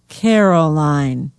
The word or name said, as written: caroline